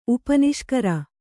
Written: ಉಪನಿಷ್ಕರ

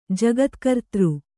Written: ಜಗತ್ಕರ್ತೃ

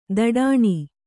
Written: ಥಟ್ಟನೆ

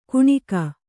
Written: ಕುಣಿಕ

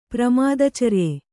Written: ಪ್ರಮಾದಚರ್ಯೆ

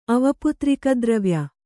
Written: ಅವಪುತ್ರಿಕದ್ರವ್ಯ

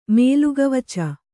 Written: ಮೇಲುಗವಚ

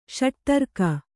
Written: ಷಟುತರ್ಕ

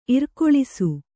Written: ಇರ್ಕೊಳಿಸು

